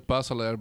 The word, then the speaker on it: Puysseleir: In Belgium, they say the Puysseleir, but the first time I checked in a hotel in the U.S., they called me Mr. De Puysseleir.